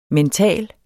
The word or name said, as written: mental